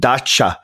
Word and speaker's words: Dacha: Yeah, no, and he used to get his top people to, you know, come over to his is it pronounced Dacha? I got in trouble for not pronouncing this Dacha. – Correctly. Dacha.